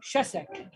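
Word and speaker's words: Shesek (שסק): If you're in Israel, come get some shesek. – Shesek.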